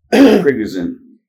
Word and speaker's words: Prigozhin: Prigozhin.